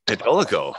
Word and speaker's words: Catolico: Technically, that's how I say it. It's technically Catolico. – Catolico. Oh, cool. – Okay. Spanish for Catholic.